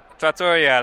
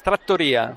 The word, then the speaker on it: trattoria: So I went to a trattoria last night. Yeah, just a little place off on some side street. – It's pronounced trattoria.